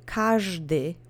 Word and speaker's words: każdy: So now that you have that fresh in your mind, say każdy with me together three times. Accent is on the first syllable.